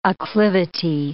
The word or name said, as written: acclivity